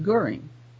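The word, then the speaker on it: Göring: if I can remember, and then Goering, just plain Goering. Goering. That's all I have to say is Goering. Don't have to make it all fancy or anything. And it's really not hard when according to this way. And it is the problem for me is in switching my thinking as I'm talking away in English to pronouncing this one word in a German way, which is not common in the English language.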